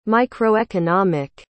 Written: microeconomic

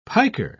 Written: piker

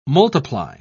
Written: multiply

